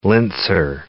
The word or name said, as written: linzer torte